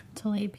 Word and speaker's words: Rashida Tlaib: Rashida Tlaib? – Tlaib, yeah.